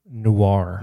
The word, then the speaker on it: Noir: Is that how you say it? – Noir. – Noir.